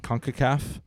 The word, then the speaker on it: CONCACAF: Is that, am I saying that right? – CONCACAF.